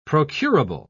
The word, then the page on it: procurable: British English